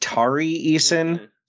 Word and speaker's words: Tari Eason: Okay, so your next name, I believe it's pronounced Tari Eason. – Yeah. – T-A-R-I Eason, like Tony Eason.